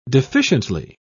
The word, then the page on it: deficiently: British English